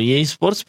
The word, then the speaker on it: EA Sports: e Sports o EA Sports, perdón, no sé si lo dije mal.